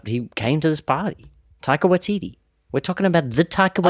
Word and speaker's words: Taika Waititi: Taika Waititi. – It's comedy. – Yeah. – That is like Tiger, but with a K, and then Waititi is like Waikiki. – Taika Waititi.